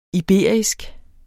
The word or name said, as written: iberisk